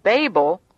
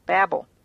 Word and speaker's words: Babel: So what was once called Babel, with a long A sound, meaning gate of the gods, was given a new name by God, Babel, with a short A, which means confusion, or is very similar to the Hebrew word for confusion.